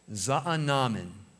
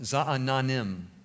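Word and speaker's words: Zaanannim: And over here, Sisera is ultimately going to run to the Oak of Za'anannim. I mispronounced it. Za'anannim.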